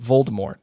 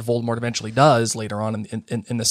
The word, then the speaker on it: Voldemort: It's not necessarily going to be about Voldemort. Voldemort's there, but... – I love that you pronounce the T.